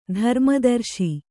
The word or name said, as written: ಧರ್ಮದರ್ಶಿ